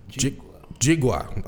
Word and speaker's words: Njigua: We have a guest here for Doing Business in Africa number 8, Anthony Njigua. – Okay, I said that. All right, there we go. The N is silent.